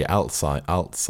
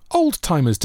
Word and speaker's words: Alzheimer's: People mispronounce that as... – I couldn't even begin to tell you. Alzheimer's. – They call it, I'm going to tell you, old-timer's disease.